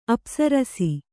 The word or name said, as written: ಅಪ್ಸರಸೆ